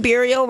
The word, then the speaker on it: burial: I was thinking Burial rhymes with Muriel, and I just thought of Muriel's wedding.